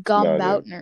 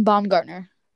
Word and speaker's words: Baumgartner: I think you said Brian Gumbardner. No, anyways, it's Brian Baumgartner.